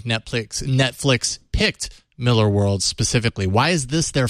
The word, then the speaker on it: Millar: A lot of people make that mistake because it's spelled Millar. – Yeah. – But he is Scottish and it is pronounced Miller.